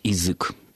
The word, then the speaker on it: язык: Все-таки нужно редуцировать, нужно произносить этот звук близкий к «и», и произносить как «язык».